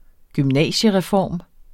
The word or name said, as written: gymnasiereform